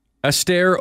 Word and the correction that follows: Alistair: Alistair Overeem.